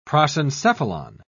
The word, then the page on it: prosencephalon: British English